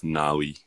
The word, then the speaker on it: Navi: I was like, "Okay," and then yeah, that's how it's pronounced Nawi, but spelt Navi.